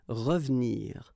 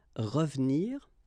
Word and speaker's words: revenir: Well, here it's exactly what we've been seeing, because it's the second syllable, so you've got re and then ve and nir, but then clearly you don't pronounce it, so you get revenir, revenir.